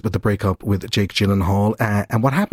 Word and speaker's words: Gyllenhaal: She was going out with a young buck by the name of Jake Gyllenhaal, is the correct pronunciation of his name.